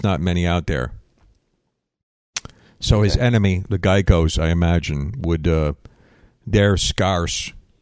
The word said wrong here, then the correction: gecko: Yeah, but I'm more shocked you're pronouncing it as Geico like the insurance company. – I said, it's a gecko.